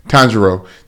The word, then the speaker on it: Tanjiro: Tanjiro.